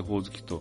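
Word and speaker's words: 鬼灯: 明かりと書いてほおずきと読む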